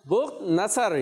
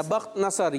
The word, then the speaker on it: بخت نصر: بخت نصر یا بخت نصر, یہ بھی اس کو کہا گیا بخت نصر, ٹھیک ہے, یوں بھی اس کا تلفظ ملتا ہے.